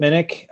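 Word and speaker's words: Minnich: I can be found on Twitter at Chip Minnick.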